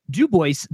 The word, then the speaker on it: Dubois: Or oh man, it's a great ride I love doing is going up to Dubois, Wyoming, and it is pronounced Dubois despite the fact that it is named after the French trapper Dubois.